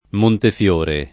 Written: Montefiore

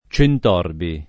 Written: Centorbi

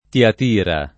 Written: Tiatira

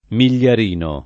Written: migliarino

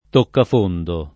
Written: toccafondo

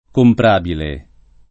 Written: comprabile

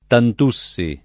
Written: Tantussi

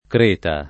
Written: creta

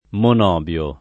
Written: monobio

